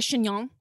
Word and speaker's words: chignon: Can I wear a wig or like a chignon? – 100%. Yes. Only if you keep saying chignon. – Is that how you say it? – Chignon. – Indeed.